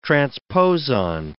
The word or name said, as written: transposon